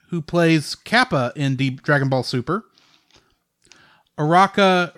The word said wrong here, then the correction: Uraraka: Akak, Uraraka was played by Lucy Christian.